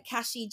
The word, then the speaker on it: Kashi: But I'm under Kashi Jalanen, which is K-A-S-H-I. And then Jalanen, which is J-A-L-O-N-E-N, but it's actually pronounced Jalanen.